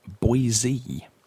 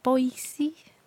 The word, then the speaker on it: Boise: has just told us that the correct pronunciation is Boise, Idaho. – Right, Boise. – Boise as in like Jay-Z.